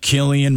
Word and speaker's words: Cillian: Best actor, Cillian Murphy.